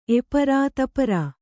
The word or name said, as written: ದಪ್ಪರಿ